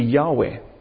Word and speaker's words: Yahweh: Now, in the Hebrew Bible, as they wrote those four letters, they did not write any vowels, which means that no one knew how to pronounce that. The best guess and the most probable way to pronounce that would be Yahweh.